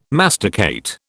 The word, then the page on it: masticate: British English